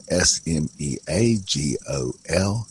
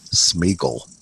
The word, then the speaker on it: Sméagol: Yeah, Sméagol, Sméagol. And I have always thought of that character as Sméagol. But then along comes movies and audiobooks and what have you. and everybody else pronounces it Smeagol. And right. My mind, every time I hear him called Smeagol, that just grates on my nerves. To me, he will always be Smeagol.